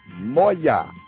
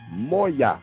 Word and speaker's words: moja: Our same old 0, Z-E-R-O. Z-R-O in Swahili. 0. Moya. M-O-J-A. Moya.